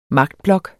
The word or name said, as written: magtblok